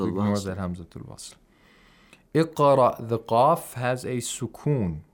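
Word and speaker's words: Iqra (اقرأ): Iqra bismi rabbika alladhi khalaqa. – And the hamza of iqra should be heard, I think. – Yes, it's hamzatul qatta.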